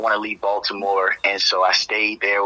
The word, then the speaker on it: Baltimore: First, I'm from Baltimore, Maryland, but let me say it properly. I'm from Baltimore, Maryland.